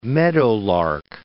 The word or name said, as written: meadowlark